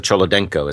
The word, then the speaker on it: Cholodenko: And I think it's Lisa Cholodenko is how you say her name.